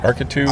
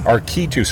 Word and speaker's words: Arkitu: I just say Arkit, how do you pronounce it? – Arkitu. – Arkitu, so A-R-K-I-T-U.